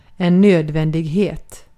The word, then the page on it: necessity: American English; IPA: /nɪˈsɛsəti/